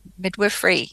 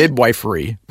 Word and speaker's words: midwifery: Eventually, you went into, let me see if I pronounce this right, midwifery? – Midwifery. Yes. – Okay, I got it. Okay. I want to say midwifery, but that's not right. – No. Midwifery.